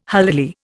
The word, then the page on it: holily: Indian